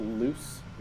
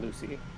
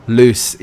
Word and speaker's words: Luce: It's pronounced Luce, not Lucy. – Oh. – L-U-C-E, Luce. – I was just going to say that too. – Loose.